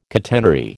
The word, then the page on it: catenary: American English